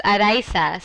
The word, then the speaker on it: Araiza: pronounces it differently, and I've just gotten to the point where I respond to it no matter how people say it. But in Spanish, it's Araiza.